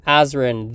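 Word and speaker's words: Azren: You can go on any social media network and search up Azrin, the language nerd. That is spelled A-Z or Z-R-E-N, the language nerd. Or, of course, at Polyglot Azrin, you can search up whichever. P-O-L-Y-G-L-O-T, A-Z or Z, depending on your country, R-E-N.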